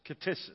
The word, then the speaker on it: ktisis: It is the word katisis. K-T-I-S-I-S, katisis, is the way that's pronounced in the Greek.